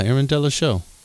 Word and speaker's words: Aaron Delachaux: Yeah, Aaron Delachaux.